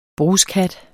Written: bruskhat

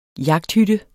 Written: jagthytte

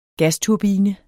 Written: gasturbine

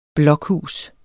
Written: blokhus

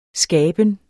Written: skaben

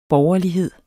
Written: borgerlighed

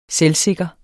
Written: selvsikker

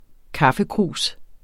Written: kaffekrus

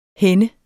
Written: henne

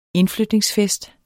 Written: indflytningsfest

